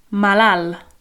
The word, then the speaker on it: malalt: That word ill is malal. – Malal. – Malal. Yeah, it's spelled with a T on the end of it, malalt, but we don't say that, okay? A bit like the word for tall, yeah, al. It's A-L-T, yeah.